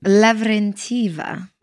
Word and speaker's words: Lavrentieva: Lavrentieva.